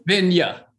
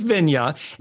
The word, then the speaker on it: Vinje: Vinje. – Nils Vinje.